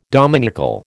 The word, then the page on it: dominical: American English